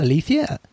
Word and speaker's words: aletheia: that is the proper pronunciation, Aletheia.